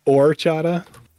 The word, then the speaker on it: horchata: Orchata? – Yeah, it's orchata. Okay. The H is silent.